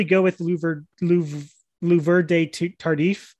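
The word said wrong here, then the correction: Duvernay-Tardif: I completely butchered Duvernay Tardif. – Duvernay Tardif, sorry.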